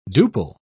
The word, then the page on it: duple: British English